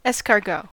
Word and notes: escargot: American English

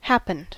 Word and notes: happened: American English

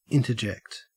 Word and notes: interject: Australian English

